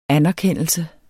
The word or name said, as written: anerkendelse